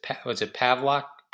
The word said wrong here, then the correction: Paxlovid: Paxlovid. So they're trying to make it rhyme with COVID in this in the new commercial. So they said, when you have COVID, it's Paxlovid or something creepy and seemingly,